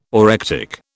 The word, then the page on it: orectic: British English